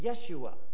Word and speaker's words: Yeshua: In fact, they would have pronounced it Yeshua, Yeshua.